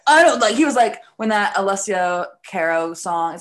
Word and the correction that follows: Cara: Alessia Cara.